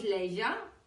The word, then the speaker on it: leisure: Aquí lo que estamos resaltando es el sonido de leisure. Leisure. Fill it up with useful pleasure. Leisure, pleasure. ¿Qué sonido es ese? Cuando lo aprendemos a pronunciar a veces lo usamos mal y decimos una S.